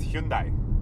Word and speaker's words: Hyundai: It's Hyundai because it's Korean.